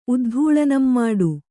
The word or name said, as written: ಉದ್ಧೂಳನಂಮಾಡು